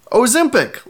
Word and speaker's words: Ozempic: Listener Josh writes in and he says, long O in Ozempic. Do your research. Followed up by listener Matt, who says, Ozempic, like Olympic.